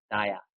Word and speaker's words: Daya: That Chandra Daya means the rising mercy of Lord Chaitanya, of Mayapur Daya. – Chandra Doya, 也就是主 Chaitanya Mahaprabhu 所升起的仁慈。 – Doya is the Bengali, Daya is the Sanskrit.